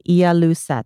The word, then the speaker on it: Ialuset: It looks like Ialuset.